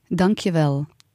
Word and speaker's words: Dankjewel: Dankjewel.